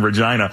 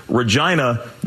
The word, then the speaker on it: Regina: Speaking of fun, some T-shirts related to the effort read, Regina, the city that rhymes with fun.